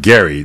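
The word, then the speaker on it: Gerry: It was actually pronounced Gary, not Jerry.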